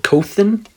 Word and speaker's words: Khotin: Khotin.